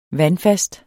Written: vandfast

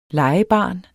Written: legebarn

